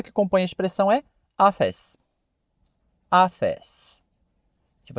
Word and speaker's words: office: E você já viu também que a palavra é dissílaba. Ela tem duas sílabas. office Simples assim, office.